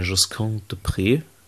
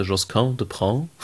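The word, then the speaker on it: Josquin des Prez: Wir nehmen gerade eine Josquin de Pré CD auf. Oder wie neulich ein Kumpel sagte, Josquin de Prin.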